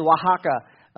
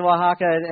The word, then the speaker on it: Oaxaca: And our missions team, MC3 missions team, shared about our partnership with Trigo y Miel in Oaxaca. Just throwing out the official pronunciations there, in Oaxaca, and looked about why we focus our missions in one area.